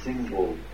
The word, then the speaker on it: символ: Вот это называлось символ. Вот, ударение вообще-то вот сюда. Поэтому в славянских книгах церковных, кстати, ударение делается тоже символ веры.